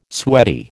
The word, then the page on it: sweaty: American English